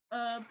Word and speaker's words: herb: I love the fact, Charlotte, you didn't pronounce the H again. Herbs. What's an herb?